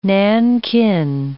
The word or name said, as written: Nankin